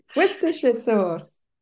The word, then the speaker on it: Worcestershire sauce: I never know how to say this, Worcester sauce, Worcestershire sauce. – Worcestershire sauce. – Sauce. Thank you. I will practice that.